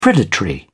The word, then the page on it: predatory: British English